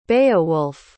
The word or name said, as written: Beowulf